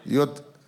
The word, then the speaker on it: Göteborg: Jönköping, Örebro, Malmö, Göteborg, Göteborg. Did I say that? How do you say that? Göteborg. Göteborg. Okay, we need to change the spelling.